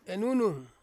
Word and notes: nunung: Ansus

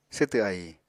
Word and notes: swtw ahi: apatani

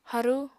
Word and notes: harú: apatani

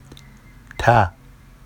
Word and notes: تَ: ta; Vafsi

